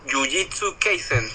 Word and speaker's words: Jujutsu Kaisen: De hecho, eh, el último que he visto, que, vi, de hecho, eh, fue el de Yujitsu eh, Jujutsu Kaisen, creo que se llama así, que es el, último que he visto. Pero me, encantó muchísimo. Disculpa si no, disculpen si no pronuncié bien el nombre. – No, pero, no, está bien, está Pero sí, de hecho es el último anime que vi.